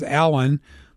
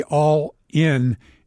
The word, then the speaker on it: Allin: And so he named me what I used to say was Alan, but is really All In. It's spelled A-L-L-I-N, Alan, or All In if you pronounce it correctly.